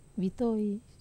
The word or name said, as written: vitoi